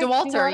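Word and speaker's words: Dauwalter: Courtney Dewater. – Mm-hmm. – Am I pronouncing her name right? – DeWalter, yeah.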